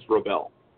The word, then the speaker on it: Robel: I probably didn't give you the right pronunciation. It's Robel.